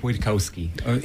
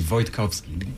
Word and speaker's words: Wojtkowski: So it's Renek Wojtkowski. In Polish, I'm originally from Poland. It would be Wojtkowski.